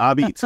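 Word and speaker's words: Apizza: But also, and help me with the pronunciation here, Sally Abeats, is that, it's written like a pizza, but is that the correct pronunciation, Abeats? – You are welcome to New Haven anytime if you say it like that. Abeats, exactly.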